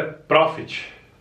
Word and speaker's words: profit: E não tem, não é que não tem, tem o T, mas não vai pronunciar. Profit. – Show. – É tipo um A, profit.